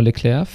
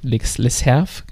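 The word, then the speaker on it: Lecerf: Landa bei Soudal Quickstep und dann noch einen jungen Fahrer, William Junior Leclerc. Leserf, wird glaube ich, ausgesprochen, der durchaus auch eine gute Grand Tour fahren kann.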